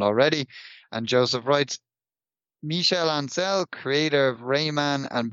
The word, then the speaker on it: Ancel: Rayman creator Michel Ancel, is that how we pronounce it? – Yeah, Ancel.